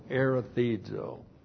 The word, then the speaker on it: erethizo: E-R-E-T-H-I-Z, long O, erethizo.